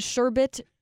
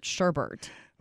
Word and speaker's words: sherbet: I know it is sherbet, but I'm going to continue to call it sherbert. – Okay. All right. And it does say in here that because it's been mispronounced for so long, that's now an alternative spelling of it.